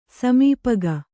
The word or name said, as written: ಸಮೀಪಗ